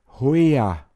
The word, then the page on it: hoia: Pinzgauer Mundart